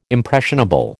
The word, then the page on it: impressionable: American English